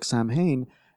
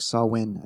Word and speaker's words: Samhain: Paul Wheatley says Halloween, originally Celtic, Samhain, which I know I slaughtered the pronunciation on, but yes. I think it's like pronounced like Sawin, I think.